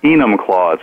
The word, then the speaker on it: Enumclaw: How is it pronounced? – Enumclaw. It's not pronounced Enumclaw like a lot of people think.